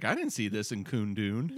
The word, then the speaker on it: Kundun: That's how it's pronounced, right? – Yeah, that's right. – Isn't it? I could cut this part. – So K-U-N-D-U-N. – Yeah. I think it's Coon Dune.